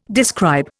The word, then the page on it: describe: Indian